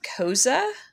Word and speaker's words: Koze: I believe it's DJ Koze. It's okay. – K-O-Z-E. But since it's German, I feel like you'd put that E in there.